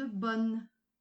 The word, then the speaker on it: bonne: N'oubliez pas de bien prononcer le nœud à la fin de bonne.